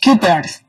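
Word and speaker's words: Cuypers: A ver, Kuipers. – Así se escribe. Es C-U-Y. – Kuipers.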